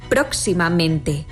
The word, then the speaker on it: próximamente: Así lo vemos en próximamente, donde se pronuncian con más fuerza las sílabas pro y men.